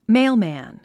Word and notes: mailman: méɪlmæ̀n; メィゥマン

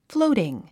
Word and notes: floating: flóutiŋ; フローティン

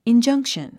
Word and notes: injunction: indʒʌ’ŋkʃən; インジャンクション

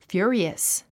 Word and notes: furious: fjú ə riəs; フィロィアス

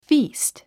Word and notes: feast: fíːst; フィースト

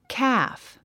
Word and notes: calf: kǽf; カーフ

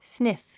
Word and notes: sniff: sníf; スニフ

